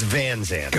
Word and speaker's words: Van Sant: Is it? It's Van Sant.